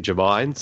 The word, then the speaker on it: Jermyn: Although, to be fair, Lance, it's actually Germain, so not to embarrass you on your own show.